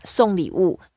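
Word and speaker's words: song li wu (送礼物): Yeah, song li wu. It's a fourth tone. And the way to pronounce it is like S-O-N-G, song. So song li wu.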